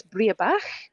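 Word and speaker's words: Breabach: I mean, forgive me for my pronunciation of this for Gaelic. I think it's Briabach. – Oh, yeah. Yeah, that sounds very good to me.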